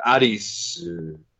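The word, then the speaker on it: arisu (アリス): A-L-I-K. What does A-L-I-K mean in Japanese? A-L-I-K. – No. Japanese pronunciation, arisu.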